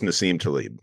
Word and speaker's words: Nassim Taleb: I was initially introduced to the concept by, I don't know if I'm pronouncing his name correctly, but Nassim Taleb. – You got it. Nicholas Nassim Taleb. Yep. – Or Taleb. Yes, sir.